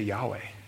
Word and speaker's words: Yahweh: And it's spelled YHWH. YHWH. When we pronounce that in English, we say Yahweh.